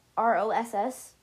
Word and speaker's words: Ross: Um or like R O R O S S Ross. Yeah. That's just how me and my cousin pronounced it when we were talking and writing it.